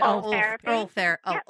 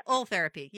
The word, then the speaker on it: Ultherapy: It's Ultherapy. – Ultherapy.